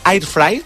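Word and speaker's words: air fryer: Una air fry, m'has dit que havia de pronunciar-ho així, molt bé.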